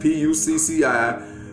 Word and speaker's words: Pucci: It's spelled P U C C I. It's pronounced Pucci, like Gucci, but with a P, not pussy, poshi, or whatever, y'all.